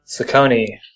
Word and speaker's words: Saccone: Saccone.